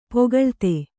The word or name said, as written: ಪೊಗಳ್ತೆ